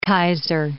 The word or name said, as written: Keizer